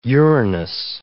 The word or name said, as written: urinous